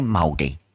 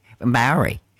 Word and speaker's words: Māori: That's how you say Māori. Māori. Māori. That's the correct pronunciation.